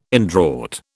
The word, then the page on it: indraught: British English